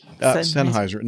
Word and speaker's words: Sennheiser: How do you pronounce that? – Sennheiser.